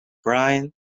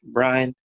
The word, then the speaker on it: Bryan: My name is Daryl Rich Brian. I'm also on Instagram, Daryl Rich Brian. – That's D-A-R-R-E-L space Rich as it's spelled space B-R-Y-A-N.